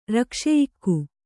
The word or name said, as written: ರಕ್ಷೆಯಿಕ್ಕು